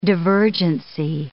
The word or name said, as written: divergency